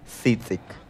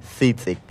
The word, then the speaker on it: tzitzic: Sí, ahí en casita quizás ya lo conocen, pero en lengua yocotán se pronuncia tzitzic. No te preocupes, vamos a volverlo a intentar. Tzitzic.